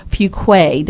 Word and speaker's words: Fuqua: Now, not able to crowd in the studio with us today, and on the phone we have Clint Fuquay.